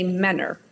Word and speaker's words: Mentor: But if you're from there, you say Mentor. – Got it. – The word looks like it's spelled mentor, but it's Mentor is how we say it.